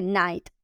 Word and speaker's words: night: Clava de pronunciación, que pronunciemos la T final, night, night. Algo muy curioso sobre la terminación GHT es que hay muchas palabras en inglés que terminan con GHT, pero resulta que tanto la G como la H son meramente decorativas, ¿ok? Y lo que de verdad se pronuncia es la T, pero eso sí, tiene que ser fuerte.